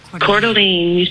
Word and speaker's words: Coeur d'Alene: Is it pronounced Coeur d'Alene or Coeur d'Alene? – Coeur d'Alene.